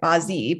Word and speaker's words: Bazi: So the Chinese word for four pillars of destiny is Bazi, B-A-Z-I. So it looks like Bazi, but it's actually pronounced Bazi.